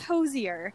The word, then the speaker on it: Hozier: So, for people who don't know, he says, get cozier with Hozier. And that's how you know how to say his name.